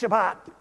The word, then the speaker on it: Shabbat: So this vayishabbat, that's Shabbat. I'm going to talk about that word in a minute. That's how you say it, by the way. In Hebrew, it's an SH sound and a hard T.